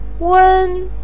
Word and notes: wen1: pinyin

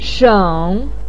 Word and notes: sheng3: pinyin

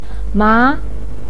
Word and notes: ma2: pinyin

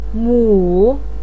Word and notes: mu3: pinyin

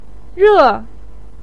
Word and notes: re4: pinyin

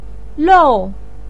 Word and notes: lou4: pinyin

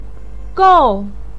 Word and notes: gou4: pinyin

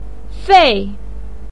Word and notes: fei4: pinyin

